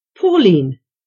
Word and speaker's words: Pauline: Pauline. – How do you spell that? – P-A-U-L-I-N-E.